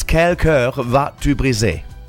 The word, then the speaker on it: Quel cœur vas-tu briser: Okay, I'm going to correct the title a little bit for the people who are listening in and probably now falling off their chair because I heard you try to pronounce French. It's Quel cœur vas-tu briser? Quel cœur vas-tu briser? That's what it's called, and it's by Geraldine from this beautiful country in the Alps called Switzerland.